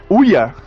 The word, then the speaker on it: OUYA: Oh Não, a pronúncia é UIA. UIA. UIA.